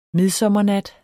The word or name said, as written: midsommernat